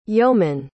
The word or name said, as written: yoman